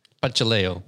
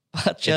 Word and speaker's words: Bacheleo: Bacheleo. – Bacheleo, wait. – Bacheleo. It's still in its Latin form. That's why it's spelt that way. The C is a ch.